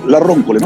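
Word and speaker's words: Le Roncole: A ver, ¿cómo se pronuncia? ¿La Roncole o la Roncole? La Roncole, ¿no? – La Roncole, sí.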